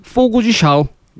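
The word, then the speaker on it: Fogo de Chão: 정확한 발음은 포구지 샤오입니다.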